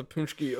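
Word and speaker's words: pączki: It's spelled P A N. – It's spelled no. It's spelled P-A-C-Z-K-I. That's Punchki.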